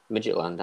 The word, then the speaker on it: Midtjylland: Am I saying that right? – Midtjylland, that's it.